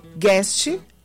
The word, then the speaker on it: Guest: Como é que se pronuncia? Guest. Gabriel Guest.